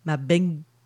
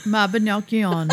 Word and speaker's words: Mabinogion: And I have to laugh because the Welsh pronunciation of this word is very, it's like, it's Mabinogion.